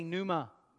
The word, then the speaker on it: pneuma: Everyone say pneuma. The P is silent. P-N-E-U-M-A.